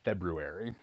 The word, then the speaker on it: February: I have to remind myself that's how you say it. February.